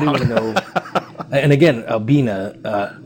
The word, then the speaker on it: Albina: And the fact that I pronounce it Albina instantly makes people recognize that I know Portland history.